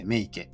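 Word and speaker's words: 雌池: あの、オス池って書いて、お池って読むんですけど、あとメ、メス池って書いて、メ池っていう、お池とメ池がこう並んでいるんですよね。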